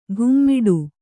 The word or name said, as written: ಘುಮ್ಮಿಡು